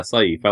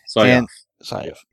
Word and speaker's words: Syaf: How would you pronounce that last name? – I'll be fine. – S-Y-A-F. – Yeah, Saif. I like that. – Saif.